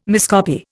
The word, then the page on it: miscopy: Indian